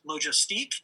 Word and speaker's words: logistique: But if you really get to the bottom line of the definition, the word is actually a French derivative word and pronounced logistique.